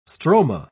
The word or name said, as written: stroma